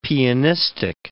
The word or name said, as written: pianistic